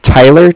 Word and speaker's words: Tyler: My name is Tyler, T-Y-L-E-R, mm-hmm. – And I'm calling with Cardiff. It's spelled C-A-R-D-I-F-F, as in Frank.